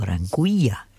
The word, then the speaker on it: Anguilla: This one's called, and it's his latest, Anguilla, or Anguilla, if you pronounce it in Spanish, A-N-G-U-I.